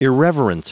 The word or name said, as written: irreverent